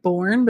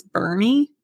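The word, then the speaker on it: Boerne: There's one, it's called, it's B-O-E-R-N-E. Looks like born, but it's Bernie. It's all traps around here, I tell you. I live in S-E-G-U-I-N.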